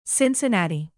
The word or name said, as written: cincinnati